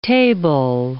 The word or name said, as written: table